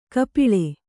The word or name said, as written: ಕಪೋಳಿ